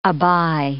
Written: abye

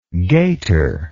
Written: gaiterless

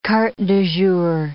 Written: carte du jour

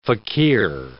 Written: fakir

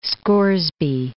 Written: Scoresby Sound

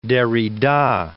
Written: Derrida

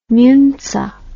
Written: muntzer

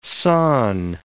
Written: sawn

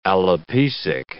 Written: alopecic

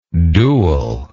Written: duelist

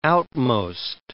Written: outmost

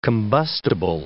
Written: combustible

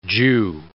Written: Jew

